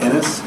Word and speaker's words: Ennis: My name is Matt Ennis, E-N-N-I-S, and I do want to reserve my right to offer rebuttal testimony later.